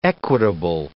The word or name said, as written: equitable